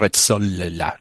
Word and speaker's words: Rezzolla: Este trabajo es de Hampolsky y Resola, con doble Z y doble L, pero yo no sé pronunciar las dobles letras en italiano. Debe ser Rezzolla, no sé, Rezzolla. Rezzolla de Hampolsky.